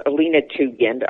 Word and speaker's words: Alina Tugend: My website is Alina Tugend.